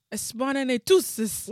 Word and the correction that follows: Expelliarmus: What the hell? – That's how it comes in my head. – Expelliarmus, bro.